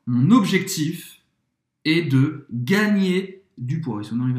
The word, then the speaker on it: gagner: Esse GN em francês tem o som do NH em português um pouco mais forte. Gagner.